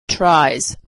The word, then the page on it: tries: z